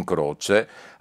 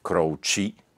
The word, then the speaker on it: Croce: Chi era dunque Jim Croce? Al secolo James Joseph Croce, come pronunciano gli americani, ma noi lo possiamo tranquillamente pronunciare croce perché evidentemente le sue erano salde origini italiane.